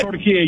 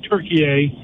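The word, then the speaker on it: Türkiye: You're emphasizing in Arabic the word. It's Turkey. It's always been Turkey. It's never been Turkey. But because of the phonetically spelling of Turkey, people weren't emphasizing the E at the end of it.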